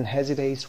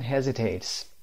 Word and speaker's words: hesitate: Number 6 often hesitates when they are speaking. Who often hesitates? Hesitate. تلفظ رو دقت داشته باشید. Hesitate. اس نخونید. Hesitate.